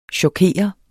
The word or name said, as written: chokere